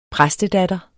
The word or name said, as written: præstedatter